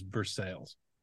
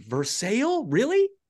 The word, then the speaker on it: Versailles: Because there's a town here where this is horse country, and the central hub of horse country is Versailles. It's extremely difficult. – Hold on a second. Like, I know. – Is that actually what it's called, or is that just how like yokels pronounce it? – It's Versailles. No, man.